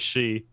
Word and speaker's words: Shi: Domi Shi, is that, or Shy or something like that? – I think that's close. It is she.